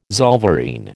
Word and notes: zollverein: American English

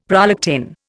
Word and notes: prolactin: Indian

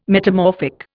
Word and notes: metamorphic: Indian

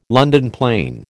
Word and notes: London plane: American English

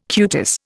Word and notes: cutis: Indian

